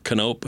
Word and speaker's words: Knope: Anybody that wants to look it up, you can either find me just under my personal page, Mark Knope, and my last name is spelled K-N-O-P-E,